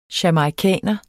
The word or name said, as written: jamaicaner